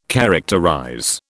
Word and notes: characterize: British English

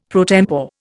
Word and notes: pro tempore: Indian

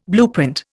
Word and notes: blueprint: Indian

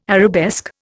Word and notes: arabesque: Indian

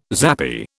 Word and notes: zappy: British English

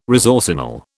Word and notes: resorcinol: British English

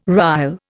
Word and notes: rile: Indian

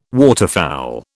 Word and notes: waterfowl: British English

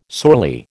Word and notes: sorely: American English

